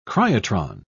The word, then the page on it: cryotron: British English